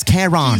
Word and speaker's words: Caron: So she spelled it C-A-R-O-N. Wow. How else are you going to pronounce that, bro? That's Karen.